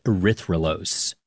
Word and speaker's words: erythrulose: And they scanned it for radio signals of different molecules, which is how they found a four-carbon sugar called erythrulose.